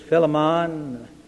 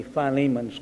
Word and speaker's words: Philemon: I know some pronounce this Philemon, some Philemon. I am of the Philemon school, for whatever it's worth.